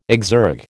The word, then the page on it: exergue: American English